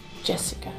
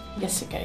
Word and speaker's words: Jessica: This is a weird thingy because when I say Jessica in Swedish, and if I say it in English, I usually say Jessica. And then people often ask me with a Y, but it's not. It's with a J, Jessica.